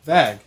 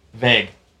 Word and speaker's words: vague: The short A sound that you're using sounds like a plastic bag versus the day. Vague.